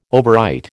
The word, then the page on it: overwrite: American English